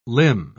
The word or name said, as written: limb